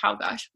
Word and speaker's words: Czolgosz: I believe it's pronounced Czolgosz.